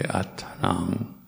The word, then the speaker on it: anattam: If an M, as in anattam, the second to last Pali word, if it comes at the end, it's pronounced very nasally, almost like an NG. So it's atanang, atanang, pariharantu.